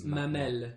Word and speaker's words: mamelle: So in French it's mamelle. – Mamelle. – Mamelle. That's the word for udder.